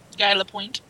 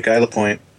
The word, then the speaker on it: Guy Lapointe: Am I saying it right? Yeah, that's right. Guy Lapointe. – Okay, Guy Lapointe.